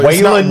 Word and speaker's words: Waylon: Is it Waylon? – Yeah. – Oh, Waylon.